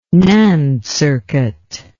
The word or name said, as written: NAND circuit